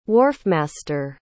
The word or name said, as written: wharfmaster